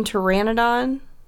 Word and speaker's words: pteranodon: There's Terry, which is a green pteranodon one of Pee-wee's closest friends. – Oh, it does have a silent P? – Yeah.